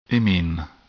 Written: imine

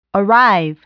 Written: arrive

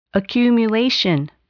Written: accumulation

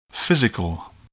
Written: physical

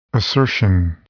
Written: assertion

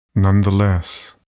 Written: nonetheless

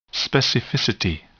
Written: specificity

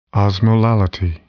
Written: osmolality

